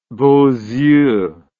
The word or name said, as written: beaux yeux